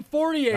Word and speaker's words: Fortier: Sam Fortier. Am I saying that right? – You are. – Sam Fortier.